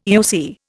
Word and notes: EOC: Indian

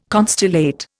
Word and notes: constellate: Indian

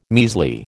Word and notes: measly: American English

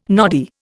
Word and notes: noddy: Indian

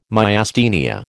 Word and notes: Myasthenia: American English

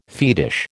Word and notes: fetish: American English

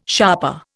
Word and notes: sharper: Indian

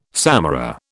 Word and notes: samara: British English

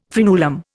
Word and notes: fraenulum: Indian

